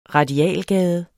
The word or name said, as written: radialgade